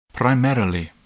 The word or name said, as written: primarily